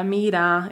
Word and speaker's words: mira: So we don't pronounce that R, yeah, mira. It just ends in like a ah sound.